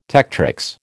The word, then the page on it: tectrix: American English